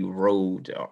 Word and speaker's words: Rhode: I'm probably the only Rode, R-H-O-D-E, that you'll find on there. Rode VL.